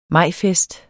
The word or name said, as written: majfest